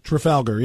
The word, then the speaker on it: Trafalgar: Professionally covering it, we have really, there's a poll that, and I don't know how to properly pronounce it, Trafalgar or Trafalgar. – Trafalgar, yeah.